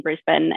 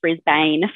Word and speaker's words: Brisbane: very warm and humid weather here in Brisbane. And good job for saying it right, because I hear so much Brisbane from other people, from people not inside Australia. We talk very lazily here.